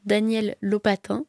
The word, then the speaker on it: Daniel Lopatin: La bande originale a été composée par Daniel Lopatin. En tout cas, c'est comme ça que Timothée Chalamet le prononce, donc j'imagine que c'est comme ça.